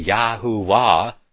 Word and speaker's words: Yahuwah: and you're not going to be offended because I say Yahuwah, where His name is phonetically pronounced in the Hebrew, even though it says the Lord in the Authorized King James Version.